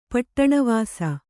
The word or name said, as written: ಪಟ್ಟಣವಾಸಿ